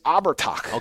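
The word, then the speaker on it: Abhartach: It is Abertach.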